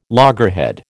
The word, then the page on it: loggerhead: American English